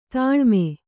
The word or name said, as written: ತಾಲೀಮು